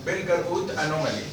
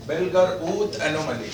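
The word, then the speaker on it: Pelger-Huët anomaly: بيلجر اوت انومالي خد بالك من النطق يا شباب عشان انت صح برضه بيلجر اوت انومالي الاتش دي سايلنت لا تنطق يبقى بيلجر اوت انومالي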